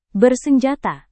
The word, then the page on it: bersenjata: Indonesian